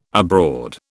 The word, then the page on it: abroad: British English